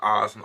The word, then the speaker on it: Asen: Also es wird Asen ausgesprochen.